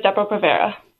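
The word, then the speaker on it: Depo-Provera: And we're going to be talking about, is it Depo Provera? Am I saying that correctly? – Yes. Yes, it is Depo Provera.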